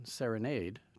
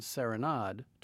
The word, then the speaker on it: Serenade: I'm using the English pronunciation, serenade, to refer to the music, and the French pronunciation, serenade, to refer to the ballet, because that is how we refer to it here at New York City Ballet.